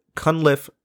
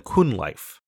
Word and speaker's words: Cunliffe: It is, as you've heard throughout this episode, Cunliffe rather than Cunliffe.